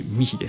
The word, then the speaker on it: mich: M-I-C-H と書いてミヒです。 – M-I-C-H です。